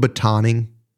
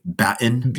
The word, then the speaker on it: batoning: You'll hear the Brits say batten. – Yes, right? I've heard it battening and I've heard it batoning, and I've always wondered which way is proper. – Yeah, it's like a police baton.